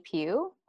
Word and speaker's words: Pue: So you can either find me on my personal Instagram is just at Bonnie Pugh. Last name is spelled P-U-E.